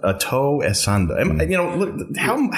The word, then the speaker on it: Ato Essandoh: It's Ato, but if you don't have a Ghanaian accent, mm-hmm. – you're not going to say it right.